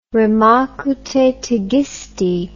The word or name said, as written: rem acu tetigisti